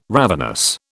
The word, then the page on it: ravenous: British English